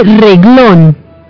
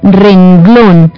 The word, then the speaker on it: renglón: No se dice reglón, se dice renglón, hay que saber pronunciar esa N, no tragársela, renglón.